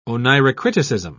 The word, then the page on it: oneirocriticism: British English